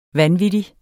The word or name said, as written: vanvittig